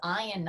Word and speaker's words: Ian: And I know he is not a fan when I say this, but his name is Ian, not Ian, even though it's spelled I-A-N.